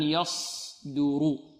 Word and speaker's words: يصدر: إذن يصدر دو، والدال مرقه يصدر، رو، والراء مفخمة.